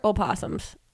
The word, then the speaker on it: opossum: But they have, so possums are opossums. – Okay. – This is a $64,000 okay question. – It's spelled opossum. – Right. But it's, you don't pronounce the O.